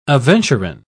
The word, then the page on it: aventurine: British English